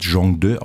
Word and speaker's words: Zhongde: Also es wird Zhongde ausgesprochen, das ist der Name, das bedeutet so viel wie Chinesisch-Deutsch.